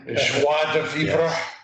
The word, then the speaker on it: joie de vivre: I know several months ago we paired penicillin with a joie de vivre.